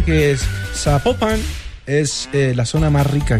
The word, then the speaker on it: Zapopan: Oiga, nos escriben, escriben los oyentes y que sapo no se pronuncia Zapopan, sino que se pronuncia Zapopan. – Zapopan.